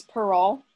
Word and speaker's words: Perrault: It's French, so I believe it's Perrault because I believe the T is silent.